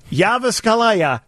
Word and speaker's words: Jyväskylä: Is from the J-Y-V-A with that umla on top. S-K-Y-L-A, umla. J-Y-V-A. – Jyväskylä.